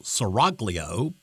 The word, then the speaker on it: Seraglio: Seraglio, by the way, is spelled S-E-R-A-G-L-I-O, which might make you think that it should be pronounced Seraglio, but it is not. It's pronounced Seraglio because it's Italian.